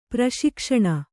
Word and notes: ಪ್ರಶಿಕ್ಷಣ: praśikshaṇa

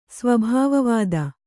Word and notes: ಸ್ವಭಾವವಾದ: sva bhāva vāda

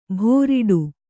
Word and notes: ಭೋರಿಡು: bhōriḍu

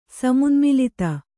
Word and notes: ಸಮುನ್ಮಿಲಿತ: Kannada

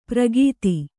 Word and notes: ಪ್ರಗೀತಿ: pragīti

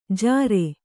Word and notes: ಝಾರೇ: Kannada

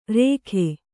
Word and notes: ರೇಖೆ: rēkhe

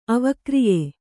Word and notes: ಅವಿಕಾರ್ಯ: avikārya

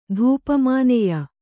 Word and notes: ಧೂಪಮಾನೆಯ: Kannada

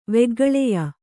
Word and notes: ವೆಗ್ಗಳೆಯ: veggaḷeya

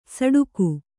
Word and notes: ಸಡುಕು: saḍuku

